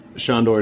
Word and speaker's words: Sándor: Sándor is a Hungarian name, and that's how I pronounce it.